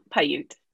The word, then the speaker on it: Paiute: So Paiute? – Oh yeah. Uh-huh. Paiute.